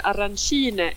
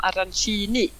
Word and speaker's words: arancine: de uttalas arancine i Palermo, medan i Catania så säger de arancini.